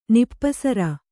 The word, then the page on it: ನಿಪ್ಪಸರ: nippasara